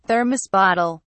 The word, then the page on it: thermos bottle: British English